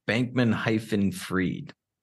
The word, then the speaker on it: Bankman-Fried: Bankman-Fried.